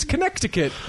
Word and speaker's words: Connecticut: It's Connecticut.